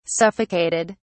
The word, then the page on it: suffocated: British English